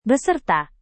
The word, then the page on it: beserta: indonesian